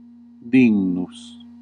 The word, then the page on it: worthy: English